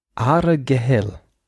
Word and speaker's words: Argyle: Argyle. Argyle. – Okay. – Wobei das nicht G und das L hinten im Hals wie im Rheinland gesprochen werden. Also Argyle.